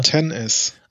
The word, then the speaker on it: XS: Also haben wir jetzt iPhone XS. Ich glaube, äh, ja, klein geschrieben. – Nein, oder? nein, 10S. – Ach ja, ja, 10S, Entschuldigung. Also Tennis.